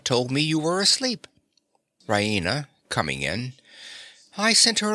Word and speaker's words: Raina: She goes to the bed, expecting to find Raina there.